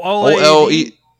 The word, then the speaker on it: OLED: Only thing is I'm concerned about is the fact that do you have to get the Oculid? That's how you pronounce it? – The what? Sorry. – The Oculid, the, you know, the new Switch. – Oh, OLED. Yeah, OLED.